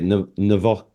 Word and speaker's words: Novichok: But, you know, allegedly he was poisoned by, how do you say it? – Novichok, that's it.